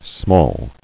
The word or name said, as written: Smalls